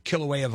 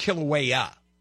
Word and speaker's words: Kilauea: I think it's Kilauea. – Yeah, well, that's the close. Hawaiian pronunciation. – Yes, that's true. – I'm just speaking the American pronunciation. – Kilauea.